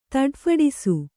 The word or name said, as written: ತಡಫಡಿಸು